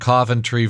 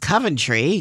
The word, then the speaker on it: Coventry: I was trying to remember that because I come from Rhode Island, and there's a, well, it's pronounced Coventry in Rhode Island, and I come here to Connecticut, and it's Coventry. And he corrected me, and he goes, it's Coventry. Not Coventry.